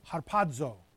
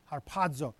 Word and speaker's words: harpazo: The word here, caught up, that's in verse 17, is a Greek word, harpazo. Harpazo. Whenever you see a Z in the transliteration of a Greek word, it's pronounced D-Z-O, harpazo.